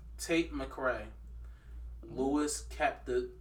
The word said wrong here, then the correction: Capaldi: I can't even pronounce it. – Louis Capaldi. – Yeah, Capaldi.